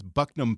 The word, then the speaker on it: Buckingham: Buckingham Palace is Bucknam Palace.